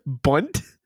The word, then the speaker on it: bundt: Bunt? – Yeah, B-U-N-D-T. – A silent D in there.